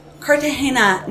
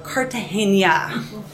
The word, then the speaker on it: Cartagena: Cartagena, not Cartagena. Saying Cartagena would be like pronouncing the S in Illinois. Harmless enough, but if you sound just like a jerk to the people who live there, I made the mistake more than once.